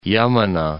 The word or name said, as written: Yamuna